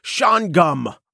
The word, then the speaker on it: Shawangunk: It's spelled Shawangunk, but it's pronounced Shawangunk by the locals.